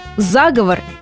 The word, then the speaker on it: заговор: На самом деле, вне зависимости от значения, ударение возможно только одно – заговор, и не иначе.